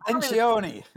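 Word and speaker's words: Lencioni: Leantoni, it's not an Italian name. – Leantoni. – There you are. See, your Italian's better than mine.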